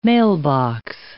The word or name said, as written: mailbox